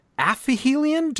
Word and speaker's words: aphelion: And at aphelion, did I pronounce that correctly? – Aphelion.